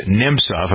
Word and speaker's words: Nemtsov: First, the death of Boris Nemtsov.